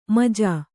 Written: ಮುಜ್ಜು

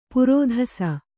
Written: ಪರದೇಸಿ

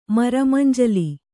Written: ಮರಮಂಜಲಿ

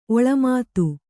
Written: ಒಳಮಾತು

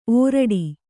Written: ಓರಿಡು

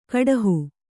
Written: ಕುಡುಹಿ

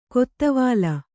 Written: ಕೊತ್ವಾಲ್

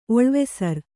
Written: ಒಳುವಸರ

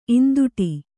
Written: ಈಂದುಟಿ

